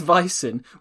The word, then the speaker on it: Vicen: His nickname is Buff because his name is Vicen, which rhymes with bison, which then becomes buffalo, which then becomes buff.